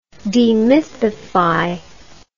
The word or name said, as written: demythify